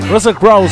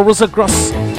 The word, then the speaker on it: Russell Crowe: Por esa cosa no me gusta pronunciar. Russell Crowe. Russell Crowe, no Russell Cross.